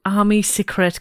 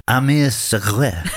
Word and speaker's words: Armée Secrète: Any idea, what Armée Secrète could mean? – Well, I mean, firstly, can I say you're not saying it right? – Okay. – It's Armée Secrète.